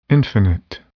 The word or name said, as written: infinite